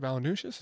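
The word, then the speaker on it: Valančiūnas: Did I pronounce that right? Valanciunas? – Yep.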